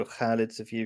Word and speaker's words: Khalid Safir: Welcome to another discussion between me, Khalid Safir, or Khalid Safir if you can pronounce it properly, and E.